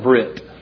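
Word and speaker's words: Brit (ברית): But basically, the Hebrew word would be pronounced in our English like Brit.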